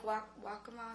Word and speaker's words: Waccamaw: And is that, you said Wilmington, is this Lake Waccamaw, is that how you pronounce it? – Yes.